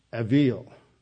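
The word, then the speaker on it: avil (אֱוִיל): Then the second category is the, in Hebrew, it'd be pronounced avil. It looks like evil in English when you transliterate it, but it's spelled ev. It's a vav, which is pronounced like a v, E-V-I-L.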